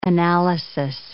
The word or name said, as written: analysis